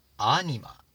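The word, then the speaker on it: anima: 次はアニマ、アニマ、頭にアクセントがあります。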